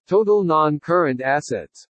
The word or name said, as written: TOTAL NON-CURRENT ASSETS